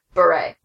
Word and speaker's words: Bure: What is it? – Bure. – Bure? – There's no L. – Candace Cameron Bure. That's awful. Her name sounds better as Bure.